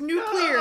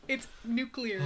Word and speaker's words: nuclear: The other one is like, I thought it was nuclear. No! And this one is like, no, no, the president pronounces it nuclear. And they argued about it. So that's how I remember. It's nuclear, not nuclear.